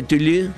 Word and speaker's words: Toulouse: Se pronuncia Toulouse, y Marsella se pronuncia Marseille.